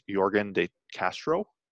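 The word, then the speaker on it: Yorgan De Castro: So the first fight on the main card, I believe, was Greg Hardy versus Jorgen De Castro.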